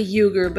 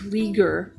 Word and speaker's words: Uyghur: Whether, I don't like saying this word just because it sounds so derogatory, but it, Uyghur, I say Uyghur, but it's pronounced Uyghur, Muslims, in China's eastern Xinjiang region, or house church Christians throughout the country.